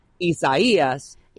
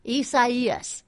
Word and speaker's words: Isaias: Pronounced so much in 2020 that I just wanted to let everyone know, just as it sounds out because it's in Spanish, Isaias. – Isaias.